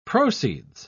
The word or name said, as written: proceeds